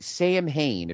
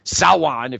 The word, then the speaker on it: Samhain: Again, NWA Samhain, if you're saying it, you know, phonetically. Samhain, if you're saying it the James Mitchell way.